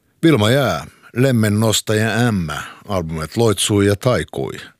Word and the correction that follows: Taikoi: Taikoi, ursäkta.